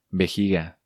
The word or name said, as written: Vejiga